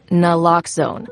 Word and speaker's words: naloxone: For the record, it's Naloxone. Na, like, is Naloxone addictive? Nuh-uh. Locks like Naloxone keeps humans safe like locks keep bikes safe, and own like Naloxone can save lives in our very own communities.